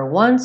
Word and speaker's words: one: For one, a letra E do one não é pronunciada. Então, for one so small, you seem so strong.